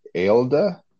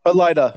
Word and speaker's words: Elaida: Most people, not Mother, of course, or I'm not going to pronounce it right, E-L-A-I-D-A, Aelda? No. – Elida.